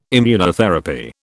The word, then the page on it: Immunotherapy: British English